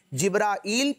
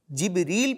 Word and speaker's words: جبرائیل: ان کا جو نام کا تلفظ ہے نا بچوں, مختلف اعتبارات سے جبرائیل پڑھنا بھی درست, جبریل پڑھنا بھی درست اور جبرائیل پڑھنا بھی درست, تینوں درست تلفظ ہیں.